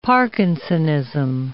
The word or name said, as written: parkinsonism